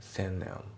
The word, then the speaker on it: send them: A の A schedule、ね、あと発音ですけど、センドの D が抜けて、さらにゼムの、あのゼムの TH のところが N で発音されますね。センネン、ね、これセンドの N に発音、あのつられて、ゼムのところがね N で発音されるんですよね。